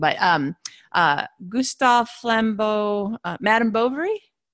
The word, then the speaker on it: Flaubert: I'd heard Flaubert. – Of it. – Flaubert.